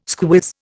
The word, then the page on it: squiz: Indian